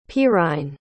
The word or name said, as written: perine